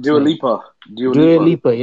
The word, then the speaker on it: Dua Lipa: Dua Lipa.